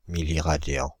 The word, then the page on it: milliradian: France (Île-de-France); /mi.li.ʁa.djɑ̃/